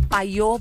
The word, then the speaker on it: Payot: É arroba paiô Brasil. Só que, ó, é PAYOT e Brasil. Paiô. É paiote Brasil. – Se escreve paiote com Y, né? Mas a pronúncia é paiô Brasil.